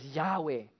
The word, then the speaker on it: Yahweh: No one actually knows for sure how to pronounce it, but people usually pronounce it as Yahweh. In the English Bible, we typically see it translated elsewhere as the Lord, L-O-R-D, capital letters, the Lord.